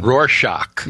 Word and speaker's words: Rorschach: How do you say that? – Rorschach.